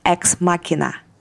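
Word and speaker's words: Ex Machina: Знаете, что вот интересно, кстати, вот мы читаем Edison Machine Works, а есть, короче, такой фильм, может быть, видели, слышали когда-то «Экс-машина» а по-английски он произносится как «экс-макина».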